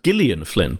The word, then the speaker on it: Gillian Flynn: I thought first of all, I think she calls it's pronounced Gillian Flynn, by the way. Oh, is it? Intriguingly, yeah, I believe so.